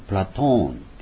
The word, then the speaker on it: プラトーン: プラトンというプラトーンというわけです。アリストテレスですね、アリストテレスですね、ソークラテスというのが本来の発音です。